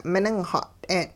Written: miningxat'een

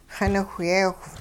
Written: xiniwhyeewh

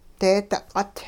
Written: deedi q'ut

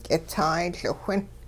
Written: k'itsaay-Liwhin

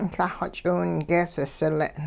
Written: Lah-xw ch'iwingees sile'n

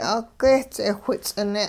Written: 'ugeh-ts'eh whits'ine'

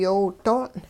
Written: yoow doong'

